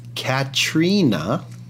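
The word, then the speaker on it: Caitríona: PJU2 on Twitter just tweeted at us, and Catriona's name is pronounced Katrina.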